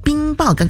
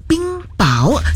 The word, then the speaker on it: 冰雹: 那在刚刚的上一个小时的六点钟的整点新闻当中呢，就是有两点要让你来留意的，就是一个呢就是这个读音方面，就是冰雹，刚刚我念的是冰雹，但其实真正的读音刚刚就搜寻了，也谢谢我们的前辈来提醒，是说这个冰雹原来正确的读音是叫做冰雹，这个第二个字呢是第二音的，所以呢在这个拼音上呢也要稍微的留意了，之前的这个读法呢一直以来其实真的是念错了。